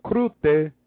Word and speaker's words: Groete: It's spelled N-A-G, Kwe Nach. It's good night, Kwe Nach. My favorite one, Krute, and it's greetings, G-R-O-E-T-E, Krute.